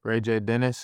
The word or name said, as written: RayJ Dennis